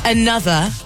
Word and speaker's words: ANOTR: They have left one vowel in there, but this artist is called Another, but it's spelled A-N-O-T-R.